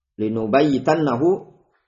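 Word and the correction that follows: lanubayitannahu (لَنُبَيِّتَنَّهُ): Afwan itu bacanya lanubayitannahu.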